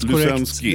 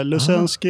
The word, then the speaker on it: Lusensky: Helt Lusensky. Korrekt. Ibland blir det Lusensky och Luseng och sådär. Lusensky är fem stjärnor på det.